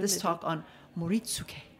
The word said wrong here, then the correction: Moritsuké: Missed a syllable. Moritsuké.